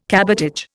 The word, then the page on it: cabotage: Indian